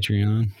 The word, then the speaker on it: Patreon: Patreon.